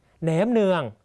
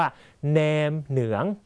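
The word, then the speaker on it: nem nướng: แหนมเนืองนะครับเรียนภาษาเวียดนามห้ามพูดว่าแหนมเนืองต้องออกเสียงว่าแหนมเหนืองนะครับ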